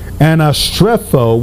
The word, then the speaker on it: anastrepho: In fact, one of the definitions of this Greek word anastrepho, which is spelled A-N-A-S-T-R-E-P-H-O, it means to behave self.